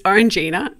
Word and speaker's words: Orangina: Is that how you say it? And the guy was like, it's Orangina, but we call it what you said on Fridays.